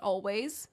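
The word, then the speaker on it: Alvvays: I was told when I first became acquainted with the band that it is pronounced Always. That's the idea. The two V's like make a W.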